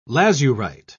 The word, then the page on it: lazurite: British English